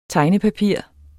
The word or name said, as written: tegnepapir